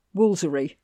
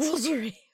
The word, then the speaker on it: Woolfardisworthy: It actually has it on the sign, Woolfardisworthy, brackets, Woolsery. Brackets. – Woolsery. – So it actually tells you how to pronounce this place.